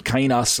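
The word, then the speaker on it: kainos (καινός): He used the word kainos or kainos, if you want to, kainos, however you want to pronounce the Greek, uh, however you were trained and studied Greek. I pronounce it kainos. Uh, that's how I w- that's how I learned it.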